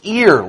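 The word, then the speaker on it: ear (ἀήρ): The word air right there is from the Greek word pronounced ear, like the appendage on the side of your head.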